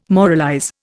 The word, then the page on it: moralize: Indian